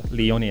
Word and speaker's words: Leoni: If they want to follow your Leoni FC profile, what platforms can they follow you at? – They can follow us on Facebook and Instagram, and it's Leoni FC. – And how do you spell Leoni? – L-E-O-N-I-F-C.